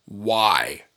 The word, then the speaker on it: Wyvern: They even have the pronunciation right here. W-A-I-V upside down E-R-N slash, you know, the next one would be capital W-Y dash lowercase V upside down E-R-N. Wyvern. – Wyvern.